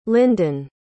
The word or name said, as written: lyndon